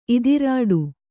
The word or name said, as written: ಇದಿರೆಡೆ